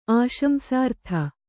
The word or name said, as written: ಆಶಂಸಾರ್ಥ